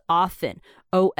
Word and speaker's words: often: 好，最后个来讲一个，这一定大家都知道了，是 often，o f t e n 啊。那很多人呢，我知道很多美国人他们喜欢把它念成 often。他们会把中间那个 t 发出来，那他这也没有错啊。